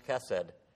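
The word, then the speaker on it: chesed (חסד): It's this Hebrew word pronounced chesed.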